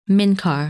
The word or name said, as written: Minkar